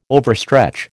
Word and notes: overstretch: American English

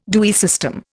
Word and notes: Dewey system: Indian

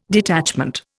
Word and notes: detachment: Indian

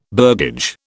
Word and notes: burgage: British English

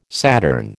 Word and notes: Saturn: American English

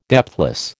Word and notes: depthless: American English

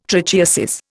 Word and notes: trichiasis: Indian

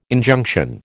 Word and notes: Injunction: American English

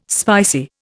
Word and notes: spicy: Indian

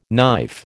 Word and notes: knife: American English

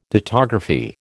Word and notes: dittography: American English

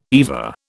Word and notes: EVA: British English

